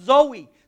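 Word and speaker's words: Zoe (ζωή): That word alive in the Greek is pronounced Zoe.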